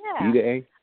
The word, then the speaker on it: Ide: I don't know how he pronounces his name, but I really like Joe Ide, I-D-E. – Ide. Joe Ide. – Ide?